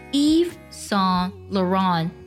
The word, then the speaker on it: Yves Saint Laurent: 这个牌子的全称呢，它读作 Yves Saint Laurent Yves Saint Laurent。